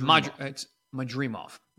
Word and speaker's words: Madrimov: Lampley was pretty pissed when I kept saying it wrong. – It's Madrimov. Okay.